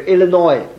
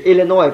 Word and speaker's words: Illinois: 讲 Chinese， multitude，你听那些广播员，他讲得快，但是那个字讲得清楚，所以我们学习讲着 multitude， he beat Elizabeth，所以这个是我们学的 Zacharias，但是请读注意啊，法文这个 S 音读不读？比如 Illinois 有没有？那没有的，是 Illinois 不是 Illinois 啊，请注意这个注意，有的时候我们会读成 Illinois 就是 Illinois，没 Illinois。